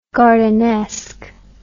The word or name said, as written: gardenesque